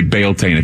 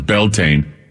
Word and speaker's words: Beltane: It's kind of, you can say Baaltane if you want, but it's Beltane is how you pronounce it.